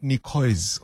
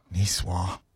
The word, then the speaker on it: Niçoise: First time I thought, oh, is that how you say it? Not Niçoise or Niçoise. Niçoise.